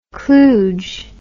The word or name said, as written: klooge